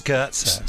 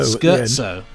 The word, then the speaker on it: Scherzo: I think it's pronounced Skirtzo. – Skirzo, isn't it? – Skirtzo. Like skirts. With so Skirtzo.